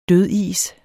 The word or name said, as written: dødis